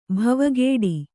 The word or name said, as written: ಭವಗೇಡಿ